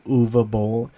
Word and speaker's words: Uwe Boll: And part of me believes that Uwe Boll was in this movie and purposely had a character pronounce Uwe Boll just so that he can let people know how to pronounce his name, Uwe Boll. Because I think most people would say Uwe Boll. But the character in this movie pronounces it Uwe Boll.